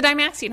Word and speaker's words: Dymaxion: The Dymaxion House.